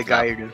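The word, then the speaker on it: Oregairu: Oregaru. Sorry, I pronounced the R's too hard.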